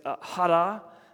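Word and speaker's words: hara (χαρά): And this word joy, it's the Greek, I've kind of got cotton mouth, so I can't really do it, but it's hara. It's like a chada, but it's like silent K.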